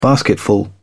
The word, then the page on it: basketful: British English